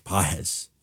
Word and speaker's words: Pages: I'm like, hey, how do you pronounce this guy's names? He's like, Páez.